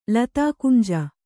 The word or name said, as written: ಲತಾಕುಂಜ